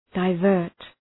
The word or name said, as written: divert